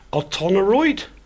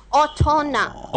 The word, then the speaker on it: otona: Otonoroid? – Otonoroid. – Otona. – Otono. – Otona. That's almost onan. Is that right? How do you say it? – Otona.